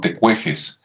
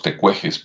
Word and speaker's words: Tecuexe: acá en los altos se les conoce como tecuejes, la pronunciación es así como México, o sea, la X como J, entonces sería el pueblo tecueje, otro los tecuejes por allá, ¿no?